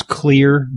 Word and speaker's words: Xlear: You may want to use clear nasal spray. It's spelled X-L-E-A-R because it contains xylitol, and that breaks up the biofilms that harbor the bacteria that can sometimes cause excess mucus production.